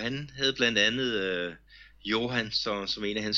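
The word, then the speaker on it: Hyoran: når man læser hans navn, men det, det udtales altså Johan.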